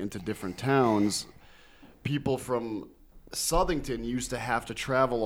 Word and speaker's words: Southington: The reason why it's called Southington, but the residents pronounce it Southington, is that Southington actually used to be Farmington, Plainville, and Southington all used to be one big town.